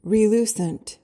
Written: relucent

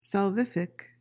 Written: salvific

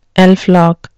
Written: elflock

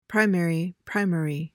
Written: primary

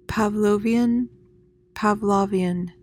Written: pavlovian

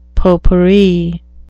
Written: potpourri

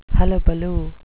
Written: hullabaloo

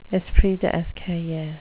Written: esprit d escalier

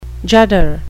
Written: judder

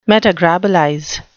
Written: metagrobolize

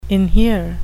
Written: inhere

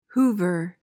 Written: hoover

